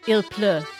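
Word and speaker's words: il pleut: Il fait pluge, pluge, pleu. So I'm trying to say it's pleu. – Il pleu.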